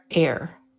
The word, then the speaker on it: AIRE: It's spelled A-I-R-E, and it stands for autoimmune regulator.